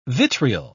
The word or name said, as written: vitriol